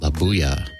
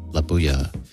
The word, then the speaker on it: La Bulla: April 30th, La Buya, which is spelled L-A-B-U-L-L-A, La Buya.